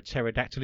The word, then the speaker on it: pterodactyl: You can never hear a pterodactyl in the bathroom, of course, because the pee is silent.